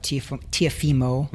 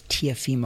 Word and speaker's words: Teofimo: So it was a 140-pound title fight, world title fight, Teofimo. Mm-hmm. I say that wrong a lot. It's Teofimo.